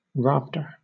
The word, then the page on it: rafter: British English